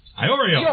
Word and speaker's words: Iorio: Mayor Pam Iorio, I can't pronounce this. – Spell it. – I-O-R-I-O. Iorio.